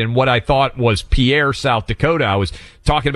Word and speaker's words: Pierre: You know, I always had pronounced it Pierre. Did you know that, Buck? It's pronounced Pierre.